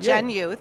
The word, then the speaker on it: Gen Youth: And you guys are working with Gen Youth, correct? Did I say it right? – Absolutely. – You did. Gen Youth. Gen Youth. Short for Generation Youth.